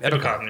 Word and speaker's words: Ebukam: Samson Ebukam. – Ebukam, yeah. I was pretty close to saying that right.